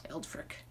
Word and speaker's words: Eldfrick: Eldfrick. A lot of people mispronounce like Eldfrid. It's like, dude, if you literally, what? It's not that hard.